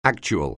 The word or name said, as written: actual